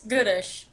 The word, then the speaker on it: Gutish: Not as bad as I've heard before. It's Gutish, but yours is a lot better than other people's pronunciations.